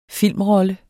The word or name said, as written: filmrolle